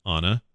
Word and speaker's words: Ana: And it is pronounced Ana, I confirmed.